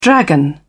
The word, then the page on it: dragon: British English